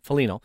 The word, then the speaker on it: Foligno: Is it Foligno? I end up saying it wrong all the time. Foligno. Yeah, I kind of why he didn't want.